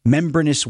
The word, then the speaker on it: membranous: membranous wings. Did I say that right? – Sure. – They're like membrane. Right, yeah.